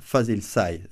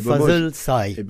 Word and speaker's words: Fazil Say: Voilà, Fazil Say, c'est comme ça qu'il faut prononcer. – Hein. Paraît-il qu'il faut dire Fazil Say.